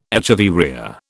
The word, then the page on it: echeveria: British English